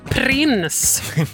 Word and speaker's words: Prince: I wanna be a lover med Prince här hos mig, Charlotta Björk i P3. Och det är så man uttalar det. Prins, säger man.